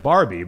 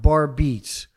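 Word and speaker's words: Barbeetz: And I tried to explain to their attorneys, who never got back to me, that it's two separate words, bar beats. That's how it's pronounced, bar beats. It's not Barbie.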